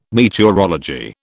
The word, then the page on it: meteorology: British English